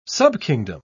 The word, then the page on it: subkingdom: British English